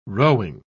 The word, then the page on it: rowing: British English